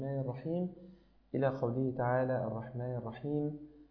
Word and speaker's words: الرحيم: يعني راعي التفخيم والترقيق بشكل أدق، ففي قوله تعالى الرحمن الرحيم الرحيم الرحمن الرحيم هكذا، يعني حاول أن تنطق الحاء وبعدها الياء مرققتين بهذا الشكل الرحيم، فلتتفضل مشكورا يا أخي الحبيب.